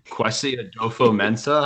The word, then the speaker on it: Kwesi Adofo-Mensah: Oh, Kwesi Adofo-Mensah.